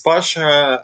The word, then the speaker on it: Basha: So my name is Basha. I'm from Russia, so it rhymes.